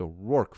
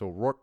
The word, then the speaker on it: O'Rourke: It's O'Rourke, fools. Don't be a dork. Pronounce O'Rourke correctly. and it rhymes with dork.